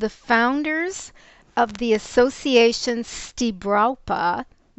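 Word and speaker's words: Stibrawpa: You can Google Stibrapa, which is spelled S-T-I-B as in Bill, R-A-W-P as in Peter, A. That's Stibrapa.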